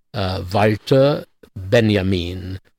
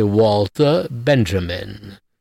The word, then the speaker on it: Walter Benjamin: Now, the next one is, and I'll pronounce him the right way, Walter Benjamin. Now, people usually, if they even know anything about him, will say Walter Benjamin.